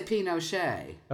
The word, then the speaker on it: Pinochet: So here's something that I read a number of years ago, is that there are four different pronunciations that are correct. And it kind of depends on your socioeconomic status as to which one you say. – Wow, interesting. – And so Pinochet, yes, Pinochet is also correct, and so is Pinochet and Pinochet.